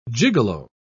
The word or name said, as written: gigolo